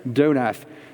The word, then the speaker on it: Donath: And farthest from me is Judith Donath, am I pronouncing it correctly? – Yes. – Donath,